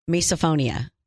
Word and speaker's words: Misophonia: Misophonia.